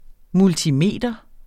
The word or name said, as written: multimeter